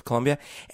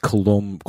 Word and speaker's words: Colombia: Colombia, and the culture is very different. – It's pronounced Colombia.